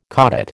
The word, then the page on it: caudate: American English